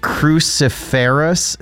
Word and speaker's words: cruciferous: Okay, I don't know how to say this word. Is it cruciferous? C-R-U-C-I-F-E-R-O-U-S. – Yeah.